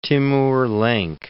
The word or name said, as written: Timur Lenk